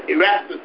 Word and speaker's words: Erastus: I think I'm saying it right. Erastus, but he himself stayed in Asia for a season.